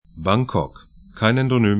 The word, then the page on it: Bangkok: 'baŋkɔk